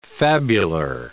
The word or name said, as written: fabular